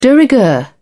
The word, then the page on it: de rigueur: British English